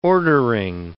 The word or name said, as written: ordering